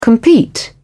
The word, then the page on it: compete: British English